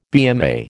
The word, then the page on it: BMA: American English